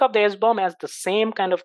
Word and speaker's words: SBOM: government, you need to have something called an SBOM, S-B-O-M. SBOM stands for, and it's pronounced SBOM,